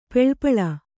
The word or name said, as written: ಪೆಳ್ಪಳ